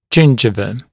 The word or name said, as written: gingiva